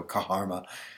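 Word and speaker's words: Kharma: It's spelled as sort of K-H, so Kaharma.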